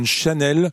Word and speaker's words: Chanel: Ça s'appelle Chanel, mais si on le prononce en français, ça donne Chanel.